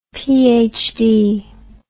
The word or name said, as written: phd